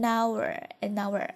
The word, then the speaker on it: hour: For nearly an hour, 这里有个连读哈，因为这个 h 它是不发音的，一个小时读作 an hour, OK, 连读。